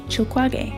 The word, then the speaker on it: Chilcuage: I first meet her when she sells me a type of chewable Mexican root called chilquague.